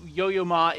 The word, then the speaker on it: Yo-Yo Ma: If you don't know who Yo-Yo Ma is, yes, I am saying that correctly.